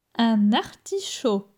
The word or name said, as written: Un artichaud